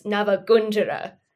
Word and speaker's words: Navagunjara: You're going for like the real traditional Indian pronunciation. It's Navagunjara.